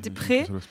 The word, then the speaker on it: Despretz: Mais surtout, il a travaillé aussi avec un storyboarder qui s'appelle, j'espère ne pas écorcher son nom, Sylvain Despretz c'est ça je, J'imagine. Des prêts. – D-E-S-P-R-E-T-Z. – Oui, ça doit prononcer des prêts.